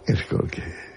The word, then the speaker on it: Skolsky: ¿Cómo se pronuncia? Porque es polaco. ¿Cómo se pronunciaría en polaco Skolsky? – Skolsky. Como tú lo has dicho.